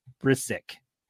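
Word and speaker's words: Bryczek: From Ukraine versus Robert Brisik. See, I did forget. You said it. – I tried to help you before we started recording. – Is that how you say it? – Brisik.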